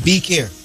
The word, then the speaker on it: Beccare: Spell it for him, Tammy. – B-E-C-C-A-R-E. – Yeah. Like Bekir.